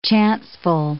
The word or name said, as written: chanceful